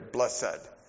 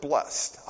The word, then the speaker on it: blessed: Some of you were raised in the King James Version, and the old school traditional grammatical has a hyphen in there, blessed, blessed. Others, in a more modern pronunciation, pronounce it blessed. I choose the second.